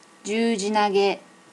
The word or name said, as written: jujinage